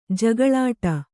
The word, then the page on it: ಜಗಳಾಟ: Kannada